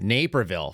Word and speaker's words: Naperville: You guys have corrected the pronunciation. Naperville. Fine. You got it. Naperville. It looks like Naperville.